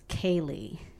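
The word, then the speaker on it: Céilí: Well, our new album is called Cats Kaylee. And Kaylee is spelled C-E-I-L-I. And that's the Irish word for big old dance party.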